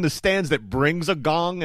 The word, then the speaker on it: Kang: It's spelled K-A-N-G, but it's pronounced Gong.